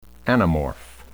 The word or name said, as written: anamorph